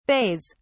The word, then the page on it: bays: British English